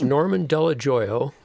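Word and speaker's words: Dello Joio: It's Norman Delajoyo.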